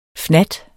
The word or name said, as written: fnat